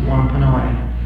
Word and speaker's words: Wampanoag: It's spelled W-A-N-P-A-N-O-A-G. Most people pronounce it Wampanoag.